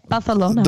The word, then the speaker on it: Barcelona: instead, that's how the, the locals say it. Barcelona.